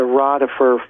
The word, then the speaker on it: Rodeffer: Okay, it's Val Thor and the Transition of George Adamski, comma, the Rotifer Files. – George Adamski and the R-O-D, how do we spell it? – R-O-D-E-F-F-E-R, Rotifer Files. – Rotifer Files.